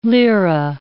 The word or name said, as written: lira